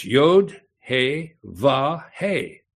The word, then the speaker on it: He: The next letter is H, the He, Yod, then He is H-E, it's pronounced He.